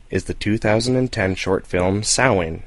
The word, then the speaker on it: Samhain: Is that how you pronounce it, John? Johnny? – That is correct. Okay, that would be the correct Gaelic way to say it. Here in the exactly. States, everyone says Samhain, but yes.